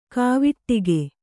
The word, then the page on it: ಕವಡುಗ: kavaḍuga